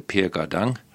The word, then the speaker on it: Gardin: Per Gardin. Ja. – Ja. – Ja. Som Rimestad udtalte Per Gardang. – Ja, Gardang, okay.